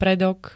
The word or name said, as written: predok